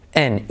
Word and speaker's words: in: En, I-N, en. No te pienses que es en, sino, ¿vale? Es una I como E, se escribe in, pero se dice en, ¿vale?